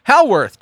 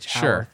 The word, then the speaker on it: Howarth: John Carpenter and Alan Howarth. – Yeah. – How do you say his name? I sound like such an idiot. – Howarth. Just say it like you mean it. – Howarth. H-A-W-A-O-A-R-T-H. – Howarth.